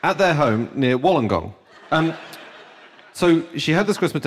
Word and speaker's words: Wollongong: Is that how it's pronounced? Is this like a... – From the reaction, I'm not sure that's how you do pronounce it. – Wollongong. You can. I am Australian.